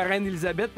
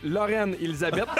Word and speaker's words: Reine Élisabeth: Je le dis bien, la Reine Élisabeth, parce qu'hier, il y a quelqu'un qui nous a écrit pour dire j'ai entendu Lorraine Élisabeth. J'étais pas sûr c'était quel hôtel.